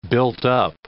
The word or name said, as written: built-up